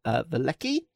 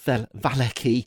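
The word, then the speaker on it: Vallaki: So when we last left our adventurers, they had been doing a little exploring of the town of Velecki. I think it's supposed to be pronounced Valecki, but I'm not going to try and be consistent because it'll never work.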